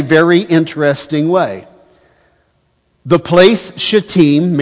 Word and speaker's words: Shittim: There is a place called the Acacia Grove. It's pronounced Shittim.